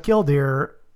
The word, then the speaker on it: killdeer: the mama killdeer, or which it pronounced killdee, I guess, in her southern rendition.